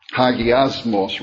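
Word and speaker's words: Hagiasmos (ἁγιασμός): Hagiamos is the pronunciation that the concordance will give. Hagiasmos, rather.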